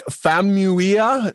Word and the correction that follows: Famuyiwa: Famuyiwa is directing and he oh, directed wow.